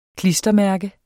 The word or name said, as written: klistermærke